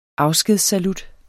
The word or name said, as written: afskedssalut